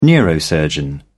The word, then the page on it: neurosurgeon: British English